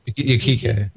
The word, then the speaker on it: Yukiko: Yukio? I can't say her name right. – Yukiko? – Yeah.